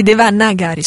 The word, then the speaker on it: Devanagari: Devaganari. – La lingua dei testi sacri. – Grazie. – Anzi, Devanagari, scusate, mi faccio sbagliare a dire. – Devanagari.